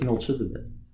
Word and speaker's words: Melchizedek: Melchizedek. I've never heard the ch pronounced. – I think it's Melchizedek.